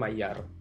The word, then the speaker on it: Maillard: nulisnya Maillard dibacanya Maillard kata internet.